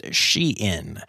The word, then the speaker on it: Shein: And um, apparently uh, it is pronounced Shein. Um, even though it is spelled kind of like shine, it is "she in fashion.